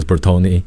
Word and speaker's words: Bertone: And Bertone, pronounced as Bertone, was one of the houses that was like 101 years old.